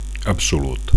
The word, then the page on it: absolut: Catalan